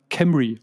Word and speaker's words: Cymru: C-Y-M-R-U soll nämlich ja der neue Name sein. Und ich habe ein bisschen mal geguckt. Ähm, das wird dann Camry ausgesprochen.